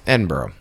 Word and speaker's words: Edinburgh: Way to pronounce it really correctly for Is that right? – Is it Edinburgh? – Edinburgh. – Fuck. Sorry.